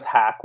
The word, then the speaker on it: Hack: My last name is Hack, which rhymes with snack or back or tack.